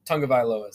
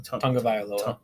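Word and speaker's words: Tagovailoa: Tungavailoa is how you say his last name. – What? Tongue? – Tungavailoa. – Well, let me try it. Viola? – That's right.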